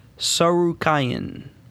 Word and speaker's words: Tsarukyan: All right, Olivier Aubin-Mercier and Armin Tsarukyan. – Yeah, the T is silent.